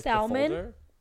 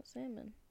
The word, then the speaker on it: salmon: Salmon. – Oh, wait, which one do you say? – Salmon. – Salmon? Yeah. Then why did you say it's like salmon? – Because I was pronouncing the L.